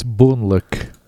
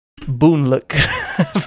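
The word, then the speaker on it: Boonlake: I don't see it there right now, but it is Alexander Pfister's newest game, Boone Lake. And sorry, I was just pulling up the page for it. – It's Boonluck. – Boonluck, because it's spelled with only one word.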